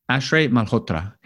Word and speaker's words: Ashray Malhotra: Am I pronouncing your last name right? Can you say it? How do you say your last name? – Ashray Malhotra. – Here to talk about how he did this,